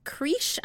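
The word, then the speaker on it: crèche: So it is creesh, I think.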